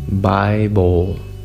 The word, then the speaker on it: Bible: En español, ah, la segunda eh, sílaba nosotros la pronunciamos como bol, Bible, bol. Pero el sonido original es bowl, Bible.